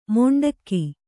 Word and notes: ಮೊಂಡಕ್ಕಿ: Kannada

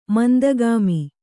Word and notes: ಮಂದಗಾಮಿ: mandagāmi

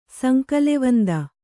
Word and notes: ಸಂಕಲೆವಂದ: kannada

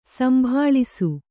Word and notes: ಸಂಭಾಳಿಸು: kannada

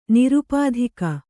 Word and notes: ನಿರುಪಾಧಿಕ: nirupādhika

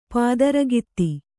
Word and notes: ಪಾತರಗಿತ್ತಿ: pātaragitti

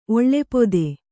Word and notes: ಓಲಪಾತ: ōlapāta

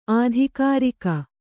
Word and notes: ಆಧಿಕಾರಿಕ: ādhikārika

